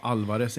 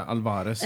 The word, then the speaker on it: Álvarez: Och man har betoningen på Alvarez, inte Alvarez.